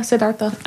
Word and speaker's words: Siddhartha: Yeah, Siddhartha.